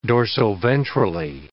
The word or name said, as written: dorsoventrally